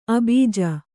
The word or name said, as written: ಅಭಿಜೆ